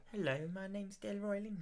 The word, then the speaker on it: Delroy Lindo: Delroy Lindo.